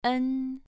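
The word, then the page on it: en: tone 2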